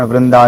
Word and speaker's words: वृंदा: इसमें वृंदा यही उसका उच्चारण वृंदा ये कई बार करते हैं। उसमें दो मात्रा हैं जो हिंदी जानते हैं, वृंदा यही इस प्रकार से उसका उच्चारण होता है।